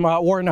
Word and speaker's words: Wartin: Wartin, how do you spell that? – W-A-R-T-I-N. – W-A-R-T-I-N, Wartin.